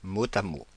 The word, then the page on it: mot à mot: France (Paris); /mo.t‿a mo/